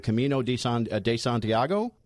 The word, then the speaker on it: Camino de Santiago: I mean, this is a movie about the, and I'm going to mispronounce this, Emilio, and you'll correct me, the Camino de Santiago? – You got it.